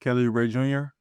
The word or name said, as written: Kelly Oubre Jr.